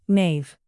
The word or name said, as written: knave